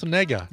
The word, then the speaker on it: Negga: It's pronounced N-E, it's N-E-G-G-A. – Ah, yes. – So they go. – The star of Preacher, Ruth Negga. – Yeah, Negga.